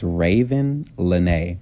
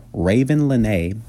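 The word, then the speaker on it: Ravyn Lenae: Her name is Raven Lene. Now, you don't spell it like Raven Simone. It's R-A-V-Y-N Lene, L-E-N-A-E. Raven Lene.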